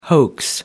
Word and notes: hoax: American English

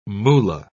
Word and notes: moolah: British English

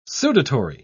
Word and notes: sudatory: British English

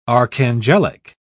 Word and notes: archangelic: British English

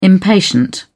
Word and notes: impatient: British English

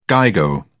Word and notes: gigo: British English

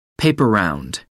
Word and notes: paper round: American English

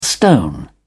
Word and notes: stone: British English

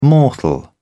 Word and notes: mortal: British English

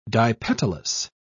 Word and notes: dipetalous: British English

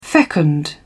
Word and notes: fecund: British English

